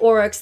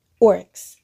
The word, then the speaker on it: aurochs: How do you spell it? Oryx. – A-U-R-O-C-H-S.